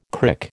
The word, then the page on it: crick: American English